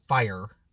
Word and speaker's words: Fire: And Richard Fryer. Richard Fire. Not Fryer. Fire.